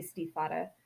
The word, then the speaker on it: Stiefvater: I'm sorry, I just pronounced it as Stiefvater. I took German. I see German words and I go, ooh, how do I pronounce that? So my apologies, it's Maggie Stiefvater. Oh my gosh, I still say it slightly German. Oh.